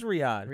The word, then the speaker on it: Riyadh: Are we pronouncing that right? Is it Riyadh? Ah, it's Riyadh.